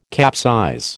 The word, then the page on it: capsize: American English